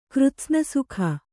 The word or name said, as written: ಕೃತ್ಸ್ನಸುಖ